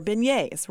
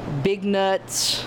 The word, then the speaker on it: beignet: It's probably the most mispronounced word, because it's B-E-I-G-N-E-T-S. So a lot of people say beignets, big nuts. We've heard some really crazy things through the years of how people pronounce it, but it's really beignet.